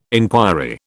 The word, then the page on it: enquiry: British English